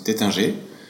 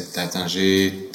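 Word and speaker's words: Taittinger: I would say we in a French way, you know, we pronounce it Tétingé. You know, it's very neutral in a neutral way, but some said Tatingé, Tatinger, but the French they said Tétingé.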